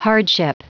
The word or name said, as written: hardship